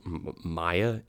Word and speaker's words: Maia: I think it's pronounced Maya, M-A-I-A. – M-A-I-A. Okay. – Maya, she's the Greek goddess of fertility.